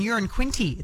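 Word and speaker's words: Uranquinty: It was Tambourine Bay Road, and I love this because Peter's texted in saying, I live in Urine Quinty. It's the same pronunciation as a number one, so it's not a big town.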